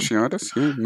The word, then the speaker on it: fasciitis: Tom Lynch's plantar fasciata, I think that's how you pronounce it. – Fasciitis, nice.